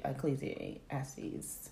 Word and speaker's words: Ecclesiastes: I mean, I think what really, when you talk about the Ecclesiastes, am I saying that right? Mm-hmm.